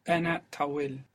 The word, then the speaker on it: tawil: Ana tawil. – Ana tawil. If you are female, you would say Ana tawla. – tawil. It's a long I.